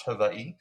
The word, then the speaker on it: Hawaii: raised in Hawaii, which I've learned on TikTok is pronounced Hawaii.